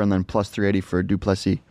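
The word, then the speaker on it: Du Plessis: Yeah, Duplessis.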